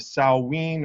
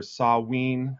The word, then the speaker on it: Samhain: Its origins traced back to the ancient Celtic, whatever you want to call it, holiday of Samhain or Samhain, however you want to pronounce it.